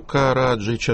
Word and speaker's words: Караджич: Скажите, пожалуйста, а на радиостанции «Свобода», я просто не слышал последние выпуски новостей, вы произносите фамилию Караджич, а с ударением на каком слоге? – А на первом.